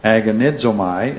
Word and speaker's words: agonizomai: The simple form of the word, and you'll see it's transliterated into English there, agonizomai is how it's pronounced,